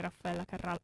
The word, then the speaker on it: Raffaella Carrà: Raffaella Carrà.